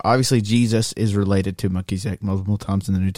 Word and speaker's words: Melchizedek: Melchizedek? – Melchizedek, yeah.